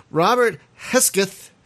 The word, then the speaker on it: Robert Hesketh: Joining us is Robert Hesketh.